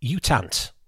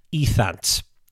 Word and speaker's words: U Thant: But if you're being pernickety and look up the Wikipedia article about how to pronounce the former Secretary General's name, Ethant. Don't expect any research beyond this point. This is my research time finished. But Ethant, apparently, is how you pronounce the bloke's name.